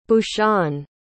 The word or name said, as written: bhushan